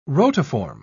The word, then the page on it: rotiform: British English